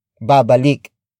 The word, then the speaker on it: babalik: Word for word it's huwag siyang babalik.